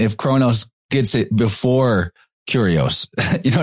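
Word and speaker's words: Kairos: Kyrios. Is that how you pronounce it? – Yeah, dude, it's exactly like what we were saying with language, that Kronos is the language and Kyrios is the feeling.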